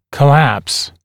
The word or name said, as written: collapse